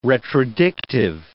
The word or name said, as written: retrodictive